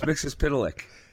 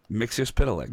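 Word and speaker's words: Mister Mxyzptlk: It's Mixer's Piddalick. – What? – Mixer's Piddalick. – Mixer's Piddalick? – Mixer's Piddalick. – Piddalick? – That's how it's pronounced.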